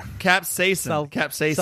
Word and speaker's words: capsaicin: I'm seeing that the capsaicin, I think I said it wrong again. Now you're getting me capsaicin. – Self-conscious. – Say it with me here. Capsaicin. – Capsaicin. – There it is.